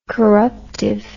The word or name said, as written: corruptively